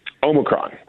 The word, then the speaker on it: Omicron: Do you know how to pronounce it? – Omicron.